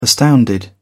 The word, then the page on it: astounded: British English